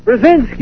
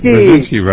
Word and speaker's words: Brzezinski: You mean you're talking about Zygmunt Brzezinski? – Brzezinski! – Brzezinski, right.